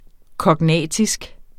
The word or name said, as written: KOGNATISKE